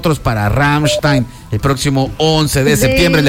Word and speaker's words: Rammstein: Rammstein. – ¡Ah! Mira. – Se dice diferente. – Me llega. No, mire, se dice Rammstein. A ver, repita. Rammstein.